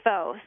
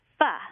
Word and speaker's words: pho: And it looks like pho. So most people pronounce it that way, but it actually is pho.